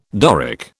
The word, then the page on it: Doric: British English